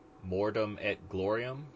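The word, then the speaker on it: Mortem et Gloriam: The next thing we had was Plastic Soldier Company is doing some 15 millimeter plastics for Mortem et Glorium. Did I pronounce that right? – Sounds pretty good.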